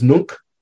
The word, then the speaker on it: nunc: N-O-N? – You. I'm sorry? – N-O-N? – Mm-hmm. Known. Short U is like the U in put. And the word I gave you was nunc. N-U-N-C.